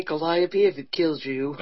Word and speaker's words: Calliope: Blame Anderson Cooper. They pay him millions of dollars a year to say Calliope. It must be the correct pronunciation.